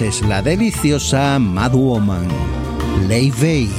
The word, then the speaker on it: Laufey: aunque su nombre se escribe Laufey, lo cual da motivos para que lo pronunciemos Lofi, la joven cantautora y productora islandesa ha indicado por sus redes que la pronunciación correcta es Leifey.